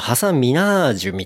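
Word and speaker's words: Hasan Minhaj: も、いくつかあって、ちょっとじゃあサクサクという感じですけど、ネットフリックスで、ハサン・ミンハジの愛国者として物申すっていう、あの、ハサミハジハサン・ミンハジ。で、これ、正直、英語発音だとハサン・ミナージュみたいな感じですね。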